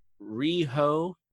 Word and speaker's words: Riho: Riho, say it right. Ah, I did it right.